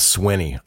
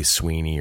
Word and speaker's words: Swinney: But my last name's Swinney. I get it all the time, man. People say Sweeney or Swiney, so I'm in the club as well with a last name that's kind of murder to spell and a murder to pronounce.